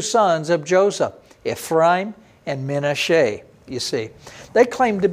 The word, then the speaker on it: Menashe: Now maybe you don't recognize it because I'm saying the Hebrew pronunciation. Ephraim, maybe you recognize it that way. Maybe you recognize Manasseh. In Hebrew, Menashe the Ephraim. That's how we say it.